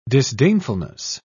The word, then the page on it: disdainfulness: British English